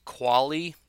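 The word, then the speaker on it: Qvale: And then right tackle, Mr. Brent Qualley is how you say his name. It's Q-V-A-L-E. I don't know how you expect a guy whose name is Q-Vale, pronounced Qualley, to play football well or do anything well, because that's just messed up.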